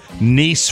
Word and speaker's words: Nice: Rapid City, South Dakota, Nice, France. Although it's spelled nice. Nice!